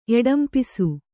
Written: ಎಡಂಪಿಸು

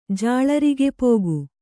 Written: ಜಾಳರಿಗೆಪೋಗು

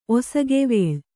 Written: ಒಸಗೆವೇಳ್

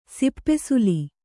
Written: ಸಿಪ್ಪೆಸುಲಿ

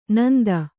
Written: ಣಾಂತ

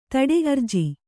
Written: ಧಡೆ

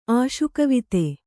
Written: ಆಶುಕವಿತೆ

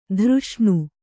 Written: ದುರೇಷಣೆ

